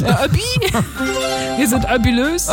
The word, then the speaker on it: Obi: Und das ist keine Abkürzung, aber Obi, das ist einfach nur Hobby auf Französisch ausgesprochen. – Oh. – Obi.